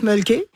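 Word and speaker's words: Mölkky: Ça, on prononce mulky. – Ouais. – Et c'est M-O-L-K-K-Y.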